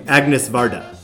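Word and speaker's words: Agnès Varda: アニエス・ベルダ Yeah, but yeah, we pronounce her name Agnes Varda in English, I think.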